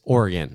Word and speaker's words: Oregon: Like I'd just be this weirdo from New Jersey that they were like, so how do you say this word? And I'm like, Oregon. And they're like, no, it's Oregon.